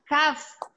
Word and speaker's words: Kaf: La pronunciación es Kaf. – Haf. – Haf. – Haf. – Haf aunque se escribe CAF.